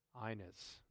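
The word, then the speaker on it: Aeneas: There he found a man named Inus. That's how you pronounce it, okay? The A-E. So you can tell why I got it mixed up, all right? All right. It's Inus.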